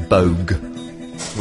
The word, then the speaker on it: Boag: Perhaps I need to start saying my name Boag, which is how everybody else in the universe pronounces it, other than my family, for some bizarre reason.